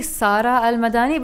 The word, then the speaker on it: Sara Al Madani: Sara, it's actually Sara Al Madani, but I'll go for Sara.